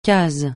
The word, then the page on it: case: francais